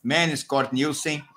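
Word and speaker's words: Magnus Cort Nielsen: Que a moça da Dinamarca lá falou que o nome dele, a pronúncia é Manos Cort Nielsen, tivesse um desempenho excelente desse jeito, Cícero.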